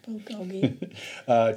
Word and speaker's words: Bulgogi: Bulgogi. Is that how you pronounce it? Bulgogi? – Bulgogi.